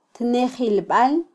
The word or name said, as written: tnejilbal